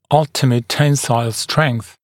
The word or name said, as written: ultimate tensile strength